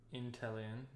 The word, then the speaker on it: Inteleon: Is it pronounced Inteleon? – Inteleon, yeah.